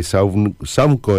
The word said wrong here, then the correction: Chaunco: No, es Chaunco. Chaunco significa encuentro de agua, Chaunco.